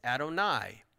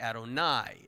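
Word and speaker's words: Adonai (אֲדֹנָי): It's Adonai. It looks like Nay, but it's actually Adonai. It's spelled that way, but it's Adonai.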